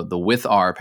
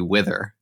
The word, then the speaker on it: withr: And you can use the local underscore env_var function from the withr package. And it's got to be withr, right? It can't be wither.